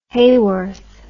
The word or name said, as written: Hayworth